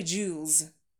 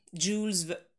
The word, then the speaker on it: Jules: В оригинале он произносится именно Жюль, Жюль, а в английском все-таки вот первое, как вы назвали, Jules, совершенно верно, через «дж» звук, вот эта буква сочетания July, например, June из той же серии Jules, Jules, yeah, Jules Verne.